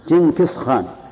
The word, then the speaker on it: جنكيز خان: فسألت عنهم رفقتي فسألت عنهم رفقتي وأحبتي أصحاب جهم حزب جنكيز خاني. – اترك لي يا أخي احذفها جنكيز خان بدنيا.